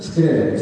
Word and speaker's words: Скрябин: Фамилия — Скрябин, залетающая искра с ударением на первый слог и твердо определенным окончанием — Скрябин.